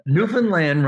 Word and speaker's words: Newfoundland: Newfoundland rhymes with understand and Labrador.